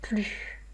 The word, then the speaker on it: plus: Donc là, mémorisez que dans la situation d'une négation ne plus, on ne prononce pas le S. Je n'en veux plus.